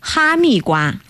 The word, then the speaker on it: 哈密瓜: 哈密瓜. – 哈密瓜.